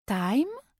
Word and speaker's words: time: Time, qui se prononce time, super bizarre, égale temps.